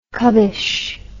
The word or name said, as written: cubbishness